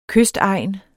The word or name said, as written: kystegn